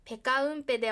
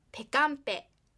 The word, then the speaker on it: ペカンペ: ですのでペカウンペではなくペカンペというふうに母音が脱落します。